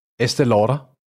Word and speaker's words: Estée Lauder: Estée Lauder.